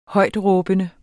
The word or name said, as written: højtråbende